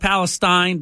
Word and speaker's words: East Palestine: Instead, spelled like Palestine, but pronounced Palestine.